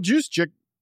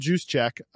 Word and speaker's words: Juszczyk: Well, that's another thing, too, is like people forget Kyle Juszczyk. – The J is silent. – I like to go with what I like to do. Kyle Juszczyk, he, you know, can make some juice through the air.